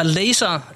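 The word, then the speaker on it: Lazer: Øhm, den er fra Laser. Er det øh, korrekt udtalt, Muff? – Det er helt korrekt udtalt, ja.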